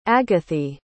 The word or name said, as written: agathi